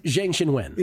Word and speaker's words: Zhang Xinwen: How do you say her opponent's name, though? I always mess that up. How do I say what? – Her opponent's name? – Zhang Xinwen.